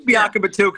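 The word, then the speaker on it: Biakabutuka: It is Biakabatuka because Tim Biakabatuka was his uncle.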